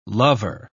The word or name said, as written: lover